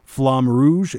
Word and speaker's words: Flamme Rouge: I'm not sure how to pronounce this, but it's Flamme Rouge. – I think it's Flamme. – Flamme Rouge. It's a Flamme Rouge.